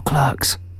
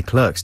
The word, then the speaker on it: Clerks: I never know whether to say Clerks or Clerks. – Well, the Americans say Clerks, don't they? We say Clerks.